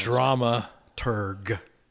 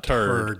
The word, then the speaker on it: dramaturg: It's called dramaturg. – Wait. How do you spell that? – Exactly the way it's sounds. – Dramaturg. Turg, not turd. Turd, not turd. Dramaturg.